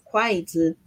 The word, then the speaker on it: kuaizi: como la pronunciación de rápido, quite. – Exacto, kuaizi.